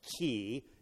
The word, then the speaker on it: kai: By the way, it's the Greek word "kai," which is pronounced "key."